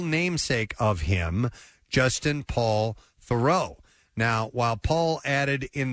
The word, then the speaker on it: Theroux: And in fact, it's not Theroux, it's Theroux. – Theroux. – As in, I walked through the door.